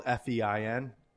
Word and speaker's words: Fein: I don't think I could do it because my name is spelled F-E-I-N.